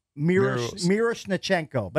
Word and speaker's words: Miroshnichenko: How do you pronounce his last name? – Miroshnichenko. But everybody calls him Miro. – Miroshnichenko, right.